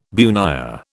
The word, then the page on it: bunya: British English